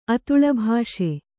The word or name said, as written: ಅತುಳಭಾಷೆ